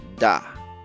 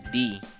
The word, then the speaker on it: the: Ahora vamos a hablar del artículo da, que significa el, la o los. Se puede pronunciar como da o también como di. Cualquiera de las dos pronunciaciones están bien.